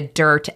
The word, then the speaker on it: Dyrt: So the number one app for the van life people is called the Dirt app, and Dirt is spelled D-Y-R-T.